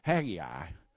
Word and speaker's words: Haggai: We read on down in Haggai, that's the way they pronounced it at the Southern Baptist Church where I grew up, Haggai, verses 5 through 9 says, my spirit remains among you, do not fear.